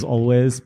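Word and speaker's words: Alvvays: Otherwise pronounced as Always, but I thought I'd add a little cheek.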